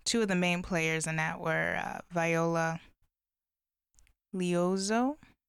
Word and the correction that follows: Liuzzo: I don't want to mispronounce her name. Liuzzo, I'm sorry.